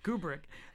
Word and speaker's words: Kubrick: Kubrick? Did I say his name right? You said it right. Oh, great job. Kubrick.